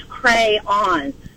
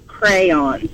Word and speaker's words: crayon: In the English language, A-Y says A, so it's crayon. Just like bay, clay, so it's pronounced crayon.